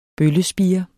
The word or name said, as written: bøllespire